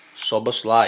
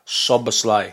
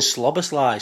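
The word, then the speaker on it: Szoboszlai: I asked him how to pronounce it, and this is what he said. – No. – Oh, hang on. – Sobber's lie. – Yeah, that Sobber's lie. He was laughing at my pronunciation at the start. One more time. – Sobber's lie. – I was saying Slobber's lie, so it's Sobber's lie.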